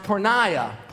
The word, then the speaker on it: porneia: It's a Greek word, and it's pronounced porneia.